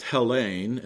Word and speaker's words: Hellene: Thus, the Greek word Hellen is used. It's pronounced Hellene in the Greek.